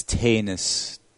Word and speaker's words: Tanishoff: Oh, actually, he's from Germany, so the A is long. So it's Tanishoff.